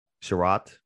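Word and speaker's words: Chiarot: Chariot.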